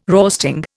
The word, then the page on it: roasting: Indian